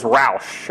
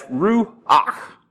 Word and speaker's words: Ruach: I used to think it was Roush. That's the way I used to pronounce it. But I did a little study and found out it's Ruach. Anyway, it's got that guttural sound.